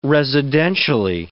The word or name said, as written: residentially